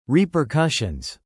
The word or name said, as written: repercussions